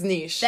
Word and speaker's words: niche: Niche rhymes with, and, you know, I think it's niche. – That is, though, it's French. – It's niche. – It's niche. – Like quiche.